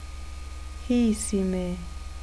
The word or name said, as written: hiisime